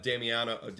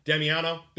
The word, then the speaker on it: Damiano: maybe you might see a Damiano. I butcher his name again. Damiano. There we go. Yeah. Damiano coming up and playing.